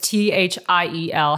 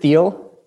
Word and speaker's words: Thiel: And you were actually awarded the prestigious Teal Fellowship, right? It's T H I E L. How do you pronounce that? – I pronounce it Thiel with yeah with the T H.